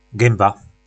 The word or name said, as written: gymba